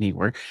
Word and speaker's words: Neewer: Yeah, that's how you say it, Neewer, uh, and they're they're putting together an app that, and uh, you can also get the Stream Deck plugin download.